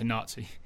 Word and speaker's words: Nati: Yeah, it's spelled Natty, N-A-T-I, and it's short for National Team. Mm-hmm. But they shorten it to Nazi.